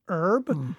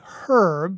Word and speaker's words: herb: Why we Americans generally don't pronounce the H in the word herb, right? By contrast, Brits say herb because, as they correctly point out, it begins with a bloody H.